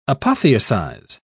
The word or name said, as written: apotheosize